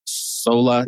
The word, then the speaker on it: Sola: But his current book, Sola, Deus? – Yes. – All right, I said it right. I wasn't sure if it was Sola or Solo.